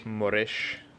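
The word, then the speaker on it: Moresche: And then straight after that, we had the eye gouge incident with, how do you pronounce it? Is Moresh or Moresk? – Moresh.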